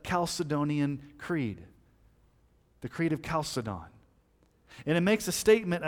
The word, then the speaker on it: Chalcedon: Some people call it Chalcedon, but I'm not a Chalcedon. I'm a Chalcedon. That's the way I pronounce it.